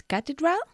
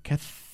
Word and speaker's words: cathedral: La palabra catedral en inglés se pronuncia cathedral. Cathedral, ¿vale? Inténtalo tú. – Cathedral. – Cathedral. – Cathedral. – Cathedral. No tiene nada que ver con catedral, ¿vale?